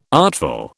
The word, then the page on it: artful: British English